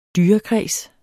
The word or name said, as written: dyrekreds